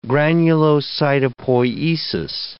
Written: granulocytopoiesis

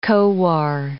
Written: Khowar

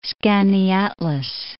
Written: Skaneateles Lake

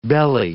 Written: belly

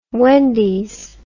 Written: wendys

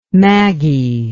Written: maggie

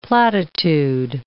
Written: platitude

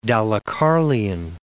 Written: Dalecarlian

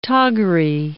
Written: toggery

